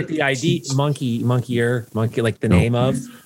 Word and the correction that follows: moniker: Moniker. – Not monkey. Moniker. Okay. M-O-N-I-K-E-R.